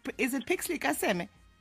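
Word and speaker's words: Pixley ka Seme: Is it Pixley Kaseme? – Yes, that's correct.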